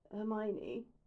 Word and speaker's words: Hermione: Hermione, he says against her suddenly, and she's pulled out of the black alley, and somehow thinking how inordinately pleased she is at the four syllables in her name.